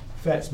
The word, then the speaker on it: FATS: FATS machine.